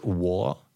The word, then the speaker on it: War: Das ist von, äh, wie spricht man das? War, so wie Krieg.